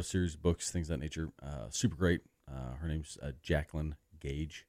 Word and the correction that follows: Gagne: Or Jacqueline Gagne is actually how it's pronounced. It's okay. – G-A-G-N-E.